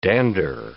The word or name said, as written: dander